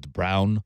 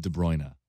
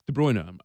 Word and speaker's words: De Bruyne: I've heard De Brown. Right? I go with De Bruyne. – De Bruyne, I'm pretty certain it's De Bruyne.